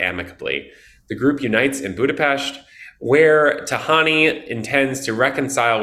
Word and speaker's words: Budapest: And by the way, great pronunciation of Budapest there.